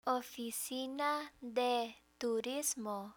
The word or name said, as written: oficina de turismo